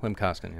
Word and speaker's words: Klim Kostin: Now, did I pronounce it? Klim Kostin? – Klim Kostin, yeah.